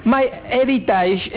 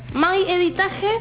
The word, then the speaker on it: My Heritage: Sería my, como my de mío, heritage, con G y con H al principio, punto es. – ¿My heritage?